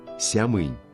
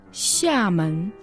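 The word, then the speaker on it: сямэн (厦门): В настоящее время россиянам привычно называть город Сямынь. – А по фонетической транскрипции в китайском языке оно должно произноситься «сямэн». «Ся» произносится в четвертом тоне, а «мэн» во втором.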